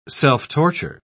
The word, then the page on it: self-torture: British English